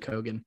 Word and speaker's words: Keoghan: I am factoring some of my favorite performances from Makari and Barry Keoghan. I think that's how you pronounce it. It's actually Keoghan.